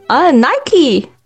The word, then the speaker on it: Nike: Nike。